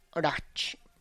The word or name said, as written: ratx